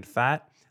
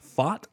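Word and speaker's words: Pfaadt: And guys, I feel a little bit like a dummy, because for all this time I've been saying Brandon Fat. I just think I learned it's called Fott, not Fat.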